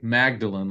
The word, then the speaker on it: Magdalen: I remember walking down a hallway at Magdalen College, because it's a collection of colleges, Oxford, and Magdalen, we would spell it like Magdalene, like Mary Magdalene, they call it Magdalen.